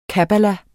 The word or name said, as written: kabbala